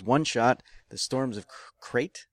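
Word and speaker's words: Crait: It's C-R-A-I-T, but I assume it's pronounced crate. That's just rate, and the C is silent.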